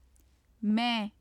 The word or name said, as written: main